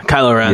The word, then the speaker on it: Kylo Ren: Kylo Ren.